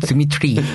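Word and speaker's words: 드미트리 (Дмитрий): 아, 드미트리. – 발음을 굴리고. – 드미트리.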